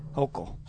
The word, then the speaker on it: Hochul: Hochul? Is that how it was? – Hochul.